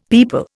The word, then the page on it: people: Indian